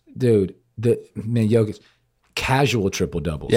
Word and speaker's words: Jokic: And that's the right way to pronounce it, Jokic. – Jokic. – Okay, get it right. Or the Joker, but it's Jokic.